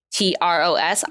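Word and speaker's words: TROS: So we keep saying The Rise of Skywalker, and I know how I've been saying T-R-O-S. I keep saying T-Ros, like T-Rex, and I would just like to confirm that is the accepted pronunciation.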